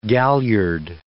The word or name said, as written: galliard